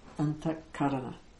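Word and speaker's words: antaḥkaraṇa: When we say that, we'll start with antakarana. Okay. The antakarana, anta means in inner comes from antara. In the Sanskrit, it becomes antaha karana antakarana. That's how it's pronounced.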